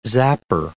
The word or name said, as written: zapper